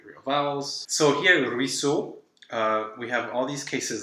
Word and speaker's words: ruisseau: So I wanted to start with Ruisseau. – Ruisseau, yes. – Only because when you look at this, it doesn't look anything like what it sounds like.